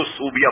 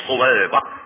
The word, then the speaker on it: صویبہ: ہم لوگ عقیدت میں نام تو رکھتے ہیں صویبہ، اس کو ہم نے اس کے تلفظ بدل اس کو صوبیہ بنا دیا. یہ صوبیہ تلفظ غلط ہے، صویبہ، یہ صویبہ.